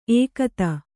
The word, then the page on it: ಏಕತ: ēkata